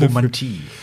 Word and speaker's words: Ovomantie: Der Fachbegriff heißt, ich muss ganz kurz nochmal nachschauen, Ovomantie. Ich weiß nicht, ob ich es jetzt richtig ausgesprochen habe, aber es gibt da einen eigenen Begriff. – Ovomantie. – Genau, und es gibt da also einen eigenen Fachbegriff für.